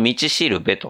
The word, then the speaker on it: 五稜星: まあ今回のね、映画は、タイトルが 100 万ドルの道しるべと。